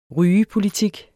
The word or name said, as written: rygepolitik